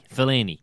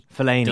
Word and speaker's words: Fellaini: With Delaney. Fellaini. – Fellaini. – Delaney, Fellaini, Delaney, Fellaini.